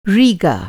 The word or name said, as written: रीगा